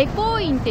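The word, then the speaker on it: 回向院: この両国西口の、交差点出てすぐ正面ぐらいにもう今日の、散策のポイントとなるお寺が見えてるんですけれども、回転の回という字に向かう院と書いてエコー院って読む、ちょっと珍しい名前のお寺です。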